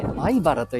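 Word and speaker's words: 米原: 米の原と書いて、前原と呼びます。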